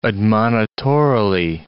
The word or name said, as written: admonitorily